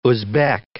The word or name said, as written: Uzbek